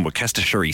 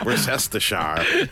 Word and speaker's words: Worcestershire: Well, there was that guy on TikTok a few months ago who was an American guy who was cooking with Worcestershire sauce, but he just said it phonetically and was like, you need some Worcestershire sauce. – Lovely. Yeah, Worcestershire.